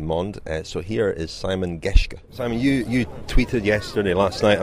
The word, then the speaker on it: Geschke: Is that the right pronounce? – Geschke, apologies.